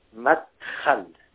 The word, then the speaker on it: Matkal: You should pronounce it like this, Matkal, like Jose in Spanish.